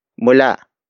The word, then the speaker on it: mula: Sila is spelled as S-I-L-A. It means they, the focus of this sentence. The next word is mula. Mula. Mula. Mula is spelled as M-U-L-A.